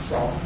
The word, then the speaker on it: NaCl: Yeah, so NACL, the Networking and Cryptography Library, which we pronounce SALT, that is an effort to deploy some boring crypto, and it has actually been deployed in quite a few applications.